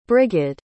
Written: Brigid

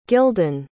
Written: gildan

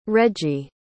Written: reggie

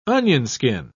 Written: onionskin

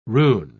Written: rune

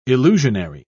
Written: illusionary

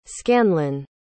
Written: scanlon

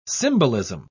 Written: symbolism

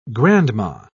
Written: grandma

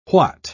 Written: what

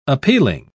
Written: appealing

